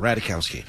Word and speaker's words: Ratajkowski: Yeah, there's a J in there, but I don't know if you pronounce that. Ratajkowski.